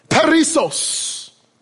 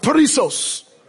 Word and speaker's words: perissos: Say that with me out loud. Perissos. Come on, say it with some oomph. Perissos. Say it like you're from over there. Kind of roll the P a little bit and then let this kind of drip.